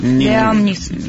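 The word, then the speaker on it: Liam Neeson: Лиам Нисон. Лаем, как он правильно произносит? – Лиам Нисон.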